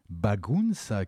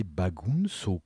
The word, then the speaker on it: Bagunça: sa mauvaise prononciation du mot portugais Bagunça, qui veut dire bazar ou désordre joyeux. Et lui, il a prononcé Bagunso, comme quoi, eh bien, une erreur de prononciation peut quelquefois conduire à la postérité.